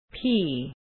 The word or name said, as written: pea